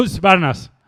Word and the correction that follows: Husqvarna: eléctrica. – Husqvarna. Husqvarna. Husqvarna.